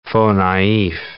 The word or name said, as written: faux-naif